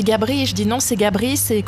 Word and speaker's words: Gabris: Gabris, hein. Avec le S qui se prononce.